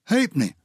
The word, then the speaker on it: halfpenny: Halfpenny. There's no F in halfpenny, is there? But it's a half penny, that's how you spell it.